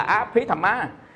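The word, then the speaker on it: Abhidhamma: Thầy để cái tên là Á Phí Tham Má, đọc là Á Bờ Hí, mà đọc là Phí, Á Phí Tham Má, Á Phí Tham Má là A Tỳ Đàm, dịch ra A Tỳ Đàm.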